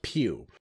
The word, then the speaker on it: Pugh: According to Google, it's pronounced Pew. P-U-G-H is Pew. There we go.